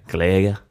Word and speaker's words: Glager: Ingen vet hur han uttalar sitt namn. – Nej. – Gleger. Gleger. – Gleger. Han stavar Glager.